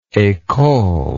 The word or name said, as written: ecole